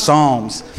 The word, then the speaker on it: Psalms: That P on the Psalms is silent. It's just Psalms.